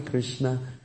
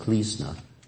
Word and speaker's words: Krishna: Like in China, Krishna is pronounced Klisna.